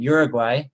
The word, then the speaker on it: Uruguay: And you and your husband and family were missionaries in Uruguay.